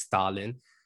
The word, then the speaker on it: Stalin: And no, that is not a mispronunciation. His last name really is Stalin, was elected chief minister, and he defeated ADA DMK leader and incumbent Adipapi K.